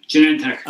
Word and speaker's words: Genentech: Yeah, Genentech.